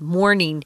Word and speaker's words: mourning: This morning is is pronounced the same but spelled differently, and it just means uh, someone who is very sad, is grieving because they've lost someone they love, mourning.